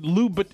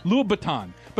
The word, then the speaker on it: Louboutin: I think it's called Louboutin. It's a B as in boy, right? – Yeah. – Louboutin.